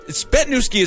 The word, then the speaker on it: Zbytniewski: Mike Ponder, Matthew Stevens, Pamela Froese, Christina Spetnewski, and now she put it in, can I just, Spetnewski is how it's pronounced, because she gave us the thing there, but it's spelled Z-B-Y-T-N-I-E-W-S-K-I.